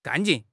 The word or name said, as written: 干劲